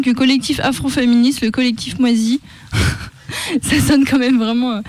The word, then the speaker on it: MWASI: Alors ça s'écrit MWASI. C'est pas Moisi pourri.